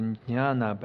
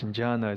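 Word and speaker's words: jñāna: Actually, in Sanskrit, the jnana, but we are going to pronounce jnana. It's easier to say.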